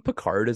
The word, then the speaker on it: Pickard: Are you saying Pickard is a bit? It's Pickard, right? – Yeah, I am. I was kind of mispronouncing it, but I know it's Pickard, but I have been saying Picard because I've been thinking of Picard from Star Trek lately.